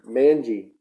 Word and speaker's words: Manji: Was it Rizwan Manji? – Majani? Manji. Manji. Yeah. Jumanji. – You put an extra syllable in his last name, I just thought.